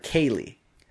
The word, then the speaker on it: Celidh: My aunt knows a woman who named her daughter Kaylee. K-A-Y-L-E-E. Well, that's how it's pronounced anyway. It's actually spelled C-E-L-I-D-H.